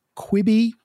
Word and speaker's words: Quibi: Notably, I think we had that very short-lived platform, Quibi, was that how you say it? – Yeah.